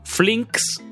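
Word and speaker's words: Flynx: Comenzamos este bloque musical con Flinks, se escribe F-L-Y-N-X, y el tema se llamó Love We Share.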